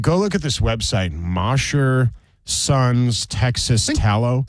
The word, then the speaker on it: Mosher: I think they said it's pronounced Mosher. – Mosher, yeah, you're right. They did say that. But if I said Mosher, would you know how to type it into a search engine? – How do you type it? M-O-S-E-R. – What does Mosher sound like? M-O-S-H-E-R, right? Because mm-hmm. that's how you spell it. – And so, Mosher, if you say sure, sounds like that too.